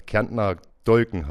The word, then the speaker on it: Kärntner Dolgen: Eine Besonderheit am Weinmannhof ist der Kärntner Dolgen. Richtig ausgesprochen, oder? – Ja, der Kärntner Dolgen heißt es.